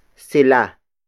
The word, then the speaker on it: sila: The next pronoun is sila. Sila. Sila. Sila is spelled as S-I-L-A.